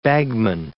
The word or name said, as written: bagman